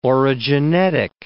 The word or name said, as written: orogenetic